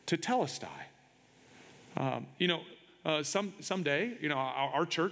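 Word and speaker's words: tetelestai (τετέλεσται): It's pronounced tetelestai, tetelestai.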